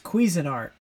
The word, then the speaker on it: Cuisinart: And that's the thing, they were selling, like, a cuisine art, like, air fryer, like, a fancy air fryer for, like, $125. – Did you say cuisine art? – Is that how you pronounce it? – It's Cuisinart.